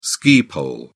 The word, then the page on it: ski pole: British English